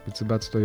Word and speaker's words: 仏罰: 仏様の罰と書いて仏罰と読むんですが、